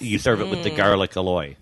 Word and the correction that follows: aioli: Yes. – Aioli.